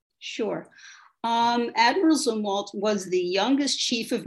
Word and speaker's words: Zumwalt: Am I saying that right? Zumwalt? – Zumwalt. – Zumwalt. – Yes.